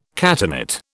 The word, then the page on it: catenate: British English